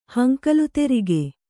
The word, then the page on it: ಹಂಕಲುತೆರಿಗೆ: kannada